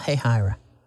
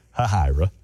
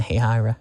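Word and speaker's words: Hahira: Tell me about Hayhira. – Funny to me that you know how to say it, because most people say Hayhira.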